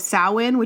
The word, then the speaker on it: Samhain: Halloween originated with the ancient Celtic festival of Samhain, which is not spelled anything like that, but I promise it's the pronunciation.